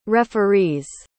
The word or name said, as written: referee's